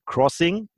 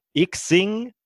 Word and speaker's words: Xing: hat also folgende äh, Aussprachen zugelassen. Crossing, Sing, Xing, Ich Sing, Ching oder Xing.